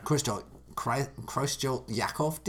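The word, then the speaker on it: Creutzfeldt-Jakob: Kruschel-Yakov disease.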